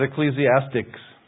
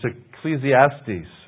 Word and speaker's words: Ecclesiastes: There's no C on the end. It's not Ecclesiastics. I hear a lot of people say, oh, we're in Ecclesiastics. I don't see a C on there. It's Ecclesiastes, okay?